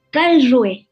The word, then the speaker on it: Karlsruhe: Huber Karlsruhe, no sé cuál sea la pronunciación correcta. – Karlsruhe. – Karlsruhe.